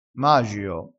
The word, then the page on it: magio: dialettale